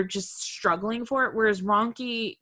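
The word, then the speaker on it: rhonchi: But I feel like with ronchi, which is spelled really weird, right? It's R-H-O-N-C-H-I. Mm-hmm. But with ronchi, I feel like, well, I guess it depends on how bad both of these are.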